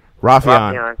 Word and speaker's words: Raufeon: Raufeon. That's close to how it's pronounced. And I think I was putting more emphasis on the E.